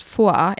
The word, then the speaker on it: foi: Malfoy, eller jag tror det uttalas Foi, F-O-I.